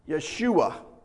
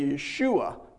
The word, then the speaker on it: Yeshua: In the actual Hebrew, it'd be Yeshua. They don't use a J sound, so it'd be Yeshua. But we always call it Joshua in English.